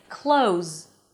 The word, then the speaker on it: clothes: That's why you can drop the th and instead of saying clothes, you can say close, close, just like close the door, close.